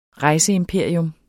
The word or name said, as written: rejseimperium